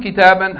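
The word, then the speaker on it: kitaban (كتابًا): Kam kitaban, how many books? Kam sayaratan, how many cars? Note that the noun with tanween in the accusative case takes an alif which is not pronounced.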